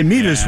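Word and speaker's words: Mevis: Which, by the way, Meavis rhymes with Beavis.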